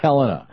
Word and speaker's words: Helena: Now, how do you say it? – Helena, okay.